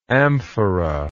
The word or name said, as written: amphoral